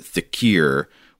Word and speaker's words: dhikr: Well, okay, so the word here is spelled D-H-I-K-R or D-H-I-K-I-R. I've heard it pronounced thicker before, but Sean gives a pronunciation guide in which he says to pronounce it thickier, which might be like a regional language variation, I'm not sure. But since Sean gives that, I'm going to say thickier instead of thicker for the rest of the email.